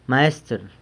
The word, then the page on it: maestr: sanlucasquiavini